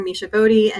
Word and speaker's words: Misha Body: But I'm available to connect with on LinkedIn, and you have my name on the screen here, Misha Body.